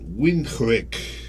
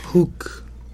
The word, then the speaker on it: Windhoek: Eh, el campus de la UNAM más cercano a, Sudáfrica es el que se encuentra en Windhoek. No sé si pronuncia Windhoek o Windhoek. Debe ser más bien Windhoek. luego pues Windhoek. – Windhoek. – Hook. Hook.